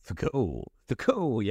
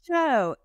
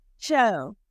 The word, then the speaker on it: THCO: Thaco. Yeah, that is a Thaco. – Thaco. Yeah, it's Joe. It's Joe. Like the T is silent. Yeah, Cho.